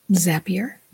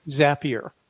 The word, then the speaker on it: Zapier: Zapier. – Zapier. And Zapier is this very smart tool that integrates all kinds of applications. So if you have a landing page application and an email application, Zapier recognizes that,